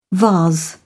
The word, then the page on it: vase: British English